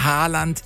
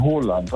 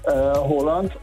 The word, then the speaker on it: Haaland: Er wird ausgesprochen Haaland, also mit O, ja. – Haaland. Und zwei A's werden als O ausgesprochen und ein A mit einem Kringel oben drauf ist eigentlich auch eine Aussprache, ein O.